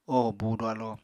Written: oho búudu alo

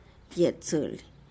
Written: yetzely

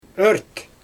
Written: ört

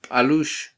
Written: balux

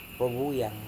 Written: rovuyang

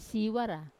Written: siwara

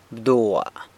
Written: bdua

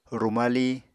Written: rumali

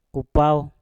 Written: kupauw

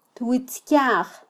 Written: twitzkyaj

